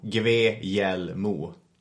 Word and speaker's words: Guglielmo: Tänk som att det stavas G-V-E-J-E-L-L-M-O. Guglielmo.